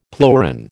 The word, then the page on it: pleuron: American English